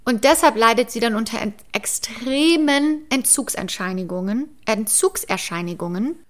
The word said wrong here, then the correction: Entzugserscheinung: Nein, falsch. Entzugserscheinung heißt es. Er Entzugserscheinungen. Habe ich es jetzt richtig gesagt? Entzugserscheinigungen. Äh. Nicht Erscheinigung, Erscheinung. Entzugserscheinung.